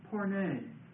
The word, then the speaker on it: porne: The Greek word that is used for this word whore or harlot is the Greek word porne. Of course, we get our word pornography from this word. Porne. And that's spelled P-O-R-N long E.